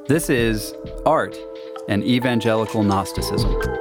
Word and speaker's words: Gnosticism: It's pronounced Gnosticism.